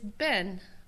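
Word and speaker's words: ben (בן): The word to build is bana, with like B-N-A, bana. And the word for son is ben, B-E-N.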